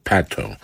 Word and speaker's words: Patto: Zespół nosił nazwę Pato. Pisze się to Patto i nazwa pochodzi od nazwiska wokalisty Mike'a Pato.